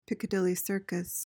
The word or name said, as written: Piccadilly Circus